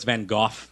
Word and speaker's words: Van Gogh: And oh, also, it's Van Gogh. That's how the Brits say it.